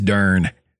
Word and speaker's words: dern: D-E-R-N. – Dern. And is it like how we would say darn? Like, pass me my dern socks. – Yes.